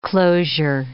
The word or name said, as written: closure